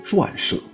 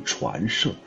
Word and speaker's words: 传舍: 传舍，有的版本可能也把它读成传舍，但是秋雨荷塘还是认为它应该读成传舍，因为这个传呢有传递、传送的意思。